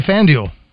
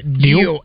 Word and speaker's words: FanDuel: Why is Roger saying duel so weird in his Oakland spot? – How do you say it? Say it. – I say FanDuel. – Oh, okay, I hear it. – What is it? – Deal? Like deal?